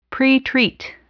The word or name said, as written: pretreat